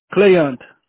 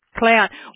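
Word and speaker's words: Cléante: C-L-E-A-N-T-E. – C-L-E-A-N-T-E. C-L-E-A-N-T-E. – Yes. – How do you say that? – Cléant. – Cléant.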